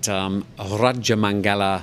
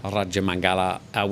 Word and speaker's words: Rajamangala: I went to a concert at Rajamangala, I hope I said that right, Stadium. – That was well done, yeah. – Rajamangala, which is a big university, and they've got a huge stadium there, sports stadium holding 55,000 people.